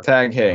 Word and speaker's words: Heuer: Heuer is a surname that comes from German word hey, meaning hay. Okay. H-A-Y is how it's pronounced. Hey or Tag Hey.